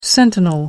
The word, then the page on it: sentinel: American English